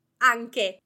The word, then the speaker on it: anche: So to recap, the pronunciation of anche is anche, with the emphasis on the first A, and hard sound K, and the final letter closed E, anche.